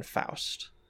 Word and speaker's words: Faust: I also looked up, it is Lauren Faust. Faust is how you pronounce it.